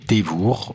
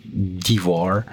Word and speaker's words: Devour: On va d'abord commencer par le morceau Dévour, donc avec un bon accent français. Je crois qu'en anglais ça devrait se prononcer Devour, euh, D-E-V-O-U-R.